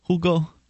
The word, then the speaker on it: Hugo: Is it Hugo? – Hugo Chavez. – You wouldn't pronounce the H, right? – Yeah, I guess you wouldn't.